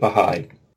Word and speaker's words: Baha'i: I see that apostrophe and I want to separate them. – It's in English, we just pronounce it Baha'i.